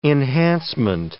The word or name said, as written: enhancement